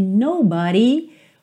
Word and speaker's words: nobody: Olha que interessante, aqui nobody, uma pronúncia típica do inglês americano, porque no britânico seria nobody, nobody, ok? Então não tem aquele d, d, d, d tem o rarara.